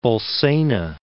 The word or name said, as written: Bolsena, Lake